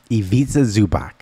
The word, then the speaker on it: Ivica Zubac: Ivica Zubac.